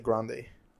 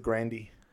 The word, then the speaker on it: Grande: She actually doesn't pronounce it Grande. She pronounces it Grandy.